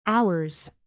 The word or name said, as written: ourselves